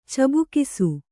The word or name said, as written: ಚಬುಕಿಸು